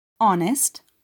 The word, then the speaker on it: honest: Our, honest, honor, ghost, choir gibi İngilizceye Fransızcadan geçmiş kelimelerdeki H harfi telaffuz edilmez.